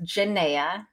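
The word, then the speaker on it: Genea: you can go to book a call with Jenea. My name is spelled with a G, G-E-N-E-A.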